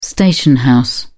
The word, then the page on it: station house: British English